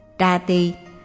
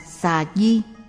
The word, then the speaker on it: Trà tỳ: Trà tỳ, danh từ xuất phát từ Phạn ngữ là Japita, cũng đọc là Xà Di, chỉ nghi thức quả thiêu nhục thân của một vị Phật hay cao tăng Phật giáo.